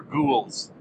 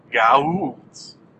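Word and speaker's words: Goa'uld: What I thought they were saying in the first 4 episodes were ghouls, but have since learned that my pronunciation of the bad guys' names in this television series was incorrect. It's not ghouls. Maybe that's sort of a slang term for them, but they're actually called Gaulds.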